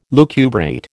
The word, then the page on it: lucubrate: American English